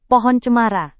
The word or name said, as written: pohon cemara